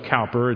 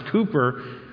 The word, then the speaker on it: Cowper: Well, the great hymn writer and poet William Cowper, although it's spelled Cowper, it's pronounced Cooper, said this, "Wait for his seasonable aid, and though it tarry, wait.